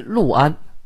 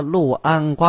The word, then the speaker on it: 六安: 设府于六安，就是有名的六安瓜片啊。您喝过没有？就是那个字儿“ 六安”，写成六，读成路。